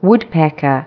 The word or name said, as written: woodpecker